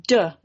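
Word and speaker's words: D'eux: The is called Duh, but it's it's spelled as in like of them or kind of yeah, D and then apostrophe E U X.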